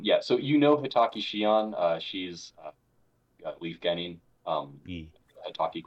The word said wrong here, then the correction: Hatake: I guess Hatake, the proper pronunciation, I guess.